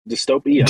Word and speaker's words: DystopAI: Is it Dystope AI? Dystope AI. Dystope AI is what we go with. Yeah, that's the one.